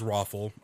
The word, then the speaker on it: ROFL: I, am pronouncing it the way that I was told it's to pre- be pronounced, which is Roffle, actually spelled out, but yes, R O F L.